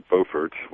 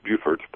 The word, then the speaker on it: Beaufort: Its county seat is Beaufort, which in South Carolina is pronounced Beaufort, but in North Carolina it's pronounced Beaufort. And they both probably actually pronounce it incorrectly, if you go back to the old European pronunciation.